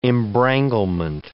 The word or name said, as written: embranglement